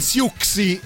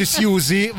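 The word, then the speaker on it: Siouxsie: Allora ho scoperto che voi dite Siuxi, invece si dice Siusi, vabbè.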